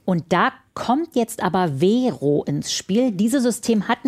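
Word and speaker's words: Wero: Vero ist ein Anbieter, der von europäischen Banken ins Leben gerufen wurde. Das ist eigentlich eine englische Abkürzung. We für wir und Ro steht für Euro. Wird aber deutsch ausgesprochen.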